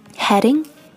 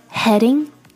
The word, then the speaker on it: heading: 者是警察 police 的时候我们说的一定是 someone is hitting me 而不是 hitting on me 这一点千万要注意还有我们今天提到的其他的几个单词 heading towards heading H-E-A-D-I-N-G heading 朝某个方向而去和 heating H-E-A-T-I-N-G heating 暖气 heading, heating 和 hitting, H-I-T-T-I-N-G, heading, heating, hitting. 这三个单词要把它区分开来的话元音发音是至关重要的也是唯一一个从听力上能够把这三个单词区分开来的一个办法。所以标准的元音发音相当重要。